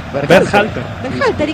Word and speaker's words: Berhalter: ¿cómo es el apellido? – ¿Cómo? – Dígalo usted. – Berhalter, ¿y qué dije yo? – No, impecable.